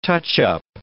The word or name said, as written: touch-up